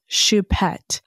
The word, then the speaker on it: Choupette: He loved his cat Choupette. I think that's how you say it. I may be butchering it, but it's Choupette. Choupette is how I think the pronunciation actually is in French.